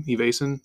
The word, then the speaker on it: Evason: Evason? – Evason.